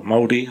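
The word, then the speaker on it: Molde: Obviously, next game, as we said, Thursday, we've got Moldy, I think they're actually pronounced, formerly managed by the manager we've just beaten.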